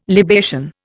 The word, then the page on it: libation: Indian